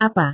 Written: apa

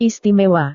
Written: istimewa

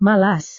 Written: malas

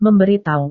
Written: memberitahu

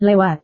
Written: lewat

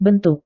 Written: bentuk

